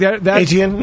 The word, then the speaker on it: Etienne: I really think that Jacksonville with Etienne, am I saying that right? – Etienne, yeah.